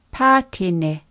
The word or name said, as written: patene